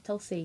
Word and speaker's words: tulsi: And is that how you say it? Tulsi? – Tulsi, yeah.